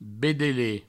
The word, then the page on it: bedelet: Provençal